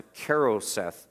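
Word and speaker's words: charoset: I think it's pronounced a Karoseth sauce at the Passover meal.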